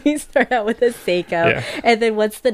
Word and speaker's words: Seiko: Wait, sorry, how do you pronounce it? – Seiko. – Seiko. – Yeah. It's okay.